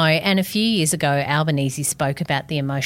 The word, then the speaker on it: Albanese: Albanese, which should actually be pronounced Albanese, he happily accepts the Aussie version of Albanese and we're going with it, was raised by his single mother, Marianne.